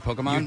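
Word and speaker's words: Pokémon: Well, we have a pair of passes to see Pokemon. Detective Pikachu, did I say it right? Pokemon? – You did.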